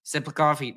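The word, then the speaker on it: Tsyplakov: Siplikov, it starts with a T, but that's how you pronounce it.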